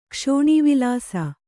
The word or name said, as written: ಕ್ಷೋಣೀವಿಲಾಸ